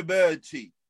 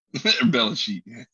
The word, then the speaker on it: Belichick: Bill Belichick. All right, Chet, my bad. – No, you said it right the first time. – Belichick.